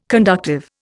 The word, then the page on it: conductive: Indian